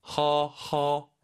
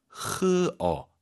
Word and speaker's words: 喝: 선생님, 발음 교정해 주세요. 허허 아니죠? – 喝 흐어 흐어. 좋아요.